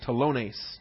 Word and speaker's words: telones: T-E-L-O-N-E-S, telones, the long O to the omega, telones, that's a singular tax collector, number 5057.